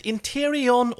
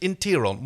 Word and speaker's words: Interion: formerly one of the producers and now also a scriptwriter, where she was asked about, like, is it Interion or is it Interon? Well, it's spelled Interion, she says, but it's got a silent I in the middle.